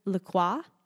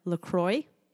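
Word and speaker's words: La Croix: Crystal says that her brain still tries to pronounce it in the French manner, Le Croix, instead of Le Croix, and that pamplemousse actually means grapefruit, which makes sense, but I didn't think about that.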